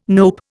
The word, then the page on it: nope: Indian